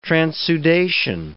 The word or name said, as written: transudation